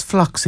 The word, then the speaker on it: phlox: What's phlox again? We've talked about that. – Yeah, the P is silent in phlox. It's P-H-L-O-X.